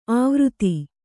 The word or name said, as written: ಆವೃತಿ